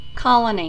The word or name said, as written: colony